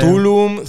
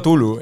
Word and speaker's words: Cthulhu: Cthulhu, Tulum, Zulu, yo digo Tulu. Eh, yo soy de los que dicen Tulu.